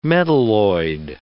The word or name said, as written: metalloid